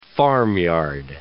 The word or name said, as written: farmyard